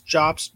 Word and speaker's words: Jobst: It says, in a nearly hour-long video posted on August 23rd, YouTuber and GoldenEye 007 speedrunner Carl Jobst, and I don't know if I'm pronouncing his name right, it's Jobst, J-O-B-S-T,